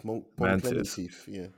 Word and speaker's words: Pom Klementieff: It was honorable mention, we have to talk about Pom Klementieff, I think that's how you say her name. – Oh yeah, Mantis. Pom Klementieff.